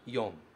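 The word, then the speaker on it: yon (四): O primeiro caractere significa 4, e aqui ele é pronunciado yon.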